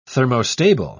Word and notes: thermostable: British English

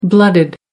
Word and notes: blooded: American English

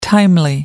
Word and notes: timely: American English

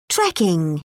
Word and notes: trekking: British English

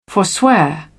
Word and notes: forswear: British English

